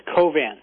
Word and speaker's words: Covance: You can check out the video there and I suppose make up your own mind on this, has filed a complaint with Covenants. Am I pronouncing the company name right? – I believe it's Covance. – Covance, thank you.